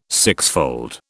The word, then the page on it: sixfold: British English